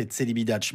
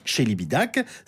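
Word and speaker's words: Celibidache: Alors, que j'ai toujours prononcé Tselibidac, mais qu'il faut prononcer Tselibidac. C'est un événement, c'est la première fois qu'on les entend sur un album et c'est le classique d'Or RTL de juin.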